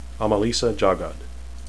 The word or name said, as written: amalisa